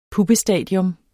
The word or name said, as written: puppestadium